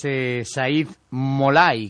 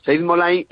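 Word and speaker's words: Saeid Mollaei: Como es Said Molay, creo que lo dije bien, ¿no? – Sí, lo has dicho perfectamente. Said Molay es campeón del mundo,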